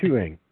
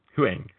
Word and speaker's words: Huang: I don't know how to pronounce that. H-U-Y-A-N-G. Hang? – Yeah. Oh no, Huang. – Huang, thank you.